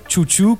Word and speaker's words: Xiu Xiu: Se prononce chouchou. Donc, mais ça s'écrit X-I-U-X-I-U.